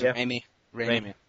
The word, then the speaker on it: Raimi: Sam Raimi is that how you say his name, by the way? – Sam Raimi. – I think it's Raimi. – Raimi. Okay. Yeah.